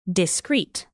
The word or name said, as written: discrete